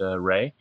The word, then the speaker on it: Reay: He has his own band called Ray, R-E-A-Y, pronounced Ray, which is just in the process.